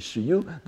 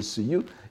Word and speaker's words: shiyu: Donc, quand vous prononcez en sino-japonais, c'est shiyu, dans la poésie japonaise, c'est suyu, et les japonais considèrent que c'est un mot purement japonais, ce qui n'est pas, euh, pas tout à fait exact, bien sûr.